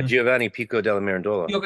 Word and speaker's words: Giovanni Pico della Mirandola: yeah, and so I'm going to mispronounce his name, Pico Mirandella, the Yeah, Giovanni Pico della Mirandola.